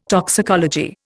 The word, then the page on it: toxicology: Indian